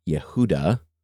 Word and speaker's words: Yehuda: And Strong says it's derived patronymically from H3063, which word is Yehuda, which is the Hebrew pronunciation of Judah.